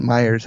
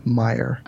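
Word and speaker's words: Meijer: Well, but they're Illinois is pronounced, it's not Illinois, it's Illinois, and Myers isn't Myers, it's Meyer. – So well, I know. – The S is silent.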